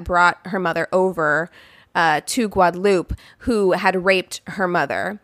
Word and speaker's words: Guadeloupe: So when I read this, it looks like Guadalupe to me. It's spelled like Guadalupe. But when I listened to the French Creole pronunciation of the island, they pronounce it Guadeloupe. So that's how I will pronounce it.